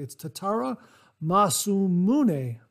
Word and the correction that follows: Masamune: Masamune Nodachi.